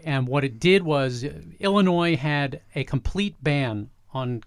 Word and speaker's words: Illinois: I didn't pronounce the S. – Yes. – Illinois.